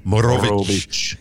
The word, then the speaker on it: Morovic: You got to roll your R's, Morovic.